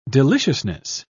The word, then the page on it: deliciousness: British English